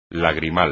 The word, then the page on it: lagrimal: espagnol